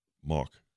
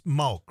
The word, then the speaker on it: Mauch: How do you pronounce M-A-U-C-H? – Mauch. – It's Mauch, right? – Mauch. – Yeah, Mauch. Back in Richland County, it's Mauch.